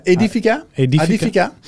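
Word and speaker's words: Aedifica: Maar sorry, terug naar Adifikia. Zeg ik het goed? – Adifica. Adif, ah, Edifica? Edifica.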